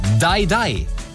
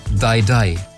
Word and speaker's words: Dai Dai: Dai Dai. Hier ist meine Spontanreaktion, nachdem Shakira das am Donnerstagabend veröffentlicht hat. 2 von 3 Ländern sind Englischsprechende und da hieße Dai Dai ausgesprochen stirb, stirb.